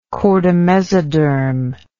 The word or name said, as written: chordamesodermic